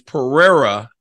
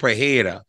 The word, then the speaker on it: Pereira: Alex Pereira, I keep saying that wrong. Like, how long will it take me to recognize that it's Pereira and not Pereira? Because a lot of Brazilians call the R, the H, and the whole confusing thing.